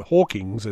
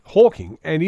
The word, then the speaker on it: Hawking: And scientists like Stephen Hawking, or Hawkings as it's mispronounced quite often, Hawking and institutions like the Vatican,